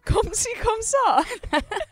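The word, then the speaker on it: comme ci comme ça: Komsika. Is that how you say it? Komsika. – Komsi komsa. – That's it. I just said komsika.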